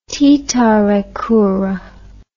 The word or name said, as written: titarakura